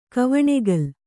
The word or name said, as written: ಕವಣೆಗಲು